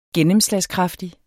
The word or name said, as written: gennemslagskraftig